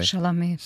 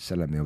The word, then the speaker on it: Chalamet: nunca sei pronunciar o nome do Timothy Chamal, Chamalé, Chalamet. Chalamet, obrigado.